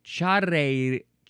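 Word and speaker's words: charrería: It's charreria. So charreria. Charreria. There's an accent on the I.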